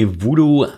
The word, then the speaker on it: VDOO: And security company Voodoo, I guess that's how you pronounce it, VDOO, did discover major vulnerabilities that lead to remote code execution in Qualcomm's QC map.